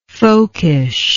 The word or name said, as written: folkishness